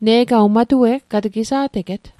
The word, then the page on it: matue'g: Mi'gmaq